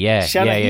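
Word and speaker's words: seanchaí: I saw somebody call him, if you want to get into that whole American-Irish thing, they said, "Rest in peace, I hope I'm saying this right, Shanachie, is that right? – Shanachie. It's a storyteller.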